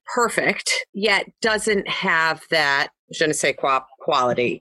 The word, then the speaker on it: je ne sais quoi: And there's the, I'm going to mispronounce it, the je sais ne quoi. – Je ne sais quoi.